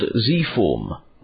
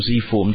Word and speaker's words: Zform: And I presume Zform is how you pronounce it since you're in America, right? – Yes. I've noticed some of the Europeans have tended to pronounce it Zform, which pointed out to me that other people pronounce the letter Z differently.